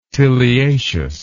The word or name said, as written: tiliaceous